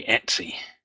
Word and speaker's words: etc: So 1566 was Steve Bickle coming back to me after the last week's show saying how /etc is pronounced, because I'd said I always pronounced it etc, and he was pointing out that I was wrong and it should be Etsy.